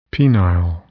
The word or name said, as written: penile